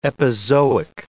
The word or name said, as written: epizoic